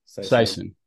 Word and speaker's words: Saisan: Is it Saisan? How do you say that? – Yeah, English version is probably Saisan. – Saisan.